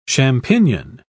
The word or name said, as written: champignon